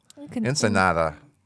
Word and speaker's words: Ensenada: Or is it nada? Nada? Ensenada. – Ensenada. – Fish taco. – Sure. – That's how you say it in Spain.